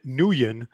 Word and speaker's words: Nguyen: Let me pronounce it the American way. Ben Nguyen defeats, of course, Tim Elliott via first round submission, rear naked choke, and Volkanovski defeated, of course, Hirota.